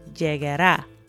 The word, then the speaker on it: llegará: So, for example, it starts out pronto llegará. So that llegar uh, is followed by uh, the a sound at the end, and that's where the emphasis is.